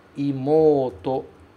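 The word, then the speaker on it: Imouto (妹): Essa palavrinha, ela se escreve com OU. Imouto. Mas a gente fala como se fosse com dois O's. Imouto. Imouto. Em japonês, OU se pronuncia O.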